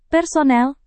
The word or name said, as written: personel